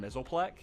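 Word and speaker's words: Mxyzptlk: He's actually a really big Superman villain, but I can't pronounce his name because you pronounce it as Mr. Mizzleplek.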